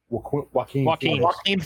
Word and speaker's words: Joaquin: Joaquin Phoenix's Joker.